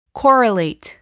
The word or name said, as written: correlate